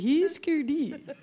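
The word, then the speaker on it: Hüsker Dü: If you pronounced all the umlauts, you would say Husker Du.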